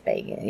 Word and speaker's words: Bagans: I say Bagans. – It's Bagans. Yeah, go on.